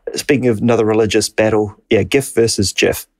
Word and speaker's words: GIF: Most people think 77.8% say it's with a hard G, like gift.